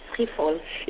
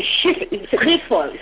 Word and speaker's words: Schiphol: Schiphol. – I know it's Dutch. The Schiphol.